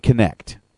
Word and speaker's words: Kinect: It's Kinetic or? – It's actually Kinect.